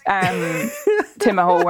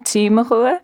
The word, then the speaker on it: Timahoe: But they do have an Ulster Irish accent, so I would have always called it, and still do, Tig Macua with a hard G, and they're calling it Timahoe. – Timahoe.